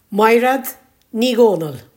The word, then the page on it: Maighread: Irish